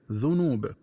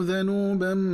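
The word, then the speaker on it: ذَنوب: فإن للذين ظلموا ذنوبا مثل ذنوب أصحابهم، والذنوب النصيب من العذاب، ومن الخطأ قراءته بضم الذال هكذا ذنوب.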